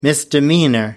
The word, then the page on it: misdemeanor: British English